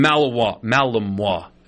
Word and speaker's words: Malinois: Anyway, they caught him finally, thanks to the savvy work of a Belgian Malinois is how you say the dog's name.